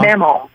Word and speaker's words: Mamaw: So Meemaw, right? I'm pronouncing it right? Meemaw? – Mamaw. – Mamaw was... – Mamaw. – Mammal was your biggest mammal. – Spell it. – M-A-M-A-W.